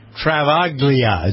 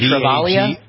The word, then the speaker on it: Travaglia: Fabio Fognini in a match with Stefano Travaglia. Is that how you pronounce his name, maybe? – Maybe Travaglia? – Travaglia, maybe without the G? – Leave the G.